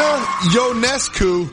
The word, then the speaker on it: Ionescu: Sabrina Ionescu, whose name I just learned how to pronounce appropriately.